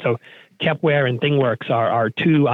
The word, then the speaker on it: Kepware: I think I've heard of something called Kepware. Did I pronounce that right? – Well, yeah,